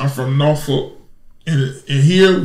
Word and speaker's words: Norfolk: It's Norfolk. That's how we say it. We country. We got country little country twang, you know. And right. It's Norfolk. So even though it looked like it's spelled, it's really spelled N A W.